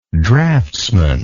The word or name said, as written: draughtsmanship